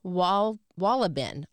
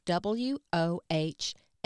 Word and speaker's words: Wohlleben: It's called The Hidden Life of Trees by Peter Wohlleben, I think is how you say his name, Wohlleben.